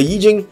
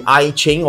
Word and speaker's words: Yi Jing: Now the Yi Jing is, some people pronounce it as the I Ching or write it as the I Ching.